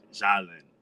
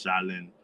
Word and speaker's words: Jalen: And my dad pronounces my name Jalen. My mom pronounces my name Jalen. So my name is, how my dad pronounces it, is Jalen La Quincy Washington.